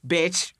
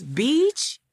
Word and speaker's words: beach: The similar word is bitch, right? – Yes, but you don't have that problem. Have you ever pronounced that wrong? – No. Because beach is same means in Japan, like beach.